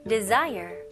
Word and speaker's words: desire: 네, 여기 스펠링은 de이지만 di로 생각하시면 좋아요. desire가 아니고요. de, 그 다음에 s는 여기서는 z 발음이 나와야 돼요. 그래서 desire가 아니고요. desire. 해볼게요.